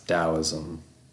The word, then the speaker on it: Taoism: So, starting with the first one, it's spelled different than said, but I've heard somebody say it, and it's Taoism.